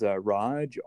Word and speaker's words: Raj: The name is Raj, R-A-J. The last name is Iyer, that's spelled I-Y-E-R.